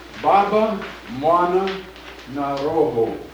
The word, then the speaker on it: Baba Mwana Na Roho: And the music distorts the Swahili pronunciation a little bit, but people don't seem to be uncomfortable with that. So we sing it a little differently than we would pronounce it.